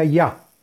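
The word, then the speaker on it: Yah: Y es el santo nombre de Yud Hei, que se pronuncia ya.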